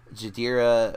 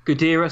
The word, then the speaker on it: Ghidorah: what did I say it wrong? Ghidorah, sorry. Yeah, I, I said that. I keep saying that one wrong. I want to call it Hydra because I'm a fan of Greek mythology and it's a freaking Hydra.